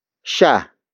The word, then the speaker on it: siya: Dumating is spelled as D-U-M-A-T-I-N-G. It means arrived, or the act of arriving has happened. The next word is sha. Sha. Sha. Sha is spelled as S-I-Y-A.